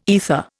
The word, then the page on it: aether: Indian